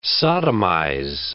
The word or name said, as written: sodomize